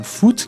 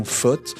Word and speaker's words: foot: Dans les cantons de Vaud, de Neuchâtel et du Valais, la prononciation foot, qui est celle pratiquée dans le camp des dominants, les Français, est ressentie de plus en plus comme une prononciation moderne et une prononciation qui est jeune. Les locuteurs s'en emparent et délaissent la prononciation faute, qui rappelle le parler des anciens et qui peut faire l'objet de moqueries si un Français l'entend.